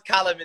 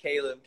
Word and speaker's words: Callum: It always makes me laugh when an American says my name, Callum. – How should I say it? – I say, well, it's pronounced Callum in the UK. You guys always like, Callum.